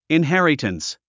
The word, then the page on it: inheritance: inhérətəns; インヘェラタンス